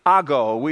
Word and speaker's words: ago: It's the Greek word ago. It's A-G-O, ago. Ago is how it's pronounced. It's transliterated from the Greek.